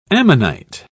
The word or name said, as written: ammonite